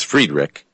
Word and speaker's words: Friedrich: It should say Frederick, but it says Friedrich. I'm pronouncing the I like they do in German.